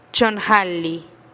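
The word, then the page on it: chonn jalih: Teotitlán del Valle Zapotec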